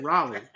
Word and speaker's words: Raleigh: From Riley, North Carolina. Riley. – Where the fuck is that? – How you say it? – Raleigh. What the fuck is Riley, North Carolina? – You know Riley.